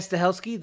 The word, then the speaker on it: Stahelski: So I'm probably gonna say this wrong. Chad Stalinsky. – Tad Chad Stahelski, the guy from uh the guy from John Wick. – Yeah. – Yep. – That, that's him. Yeah. – What's how do you say his last name? I think it's Chad Stahelski. Stahelski. – All right. I think so.